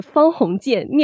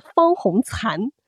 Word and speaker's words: 方鸿渐: 嗯今天是我第二次读《围城》，我惊讶的发现，跟室友聊起《围城》的时候，我一直把男主角方鸿渐念成了方鸿残，就是惭愧的惭，就是竖心旁的残。男主角明明叫方鸿渐，三点水加一个